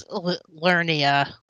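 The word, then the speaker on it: Liurnia: It is Lernia. Yeah. It's spelled weird, but we'll just say Lernia.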